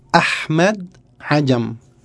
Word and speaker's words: Ahmed Ajam: Como mi nombre, Ahmed Ajam. – Ahmed Ajam. – Sí, una isla. – Me encanta eso. Ahmed. La H entonces es como una J. Ahmed Ajam. – Se pronunciación es Ahmed Ajam.